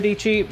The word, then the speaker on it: Dicci: Her name is Sarah Dicci, rhymes with peachy.